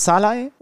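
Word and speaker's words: Szalai: haben, nicht, dass Kimi schlecht war, sondern wir haben einen Ungarn zum Man of the Match gemacht, nämlich Adam, jetzt muss ich aufpassen, Adam Scholloy. – Ich Scholloy, spricht ja. richtig aus. – Wobei der genau. spricht sich selber äh, auch übrigens unterschiedlich aus. Also es gibt äh, Interview-Schnipsel von ihm, wo er sich selber vorstellt als Salai und als Scholloy.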